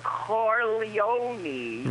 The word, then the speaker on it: Corleone: You pronounce it like the senator at the beginning of John Godfather 2. – Oh, Senator Godfather. Proud to be here with Michael Corleone.